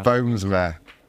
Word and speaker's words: Boomsma: And just to confirm, the pronunciation of your surname is Bomsma. Bomsma.